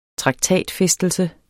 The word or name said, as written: traktatfæstelse